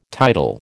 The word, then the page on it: tidal: American English